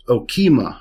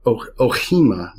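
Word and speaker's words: ochema: The word used there is okima or ohima. You'll hear different pronunciations of it, but essentially okima, which means a chariot.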